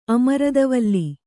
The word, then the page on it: ಅಮೃತವಲ್ಲಿ: amřtavalli